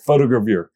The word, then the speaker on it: photogravure: it's a French word, photogravure.